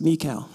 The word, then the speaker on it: Michal: I wasn't sure how to pronounce, but it's Michal.